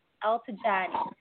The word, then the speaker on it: Eltigani: It's Muhga Eltigani. So both of the G's are pronounced like a J.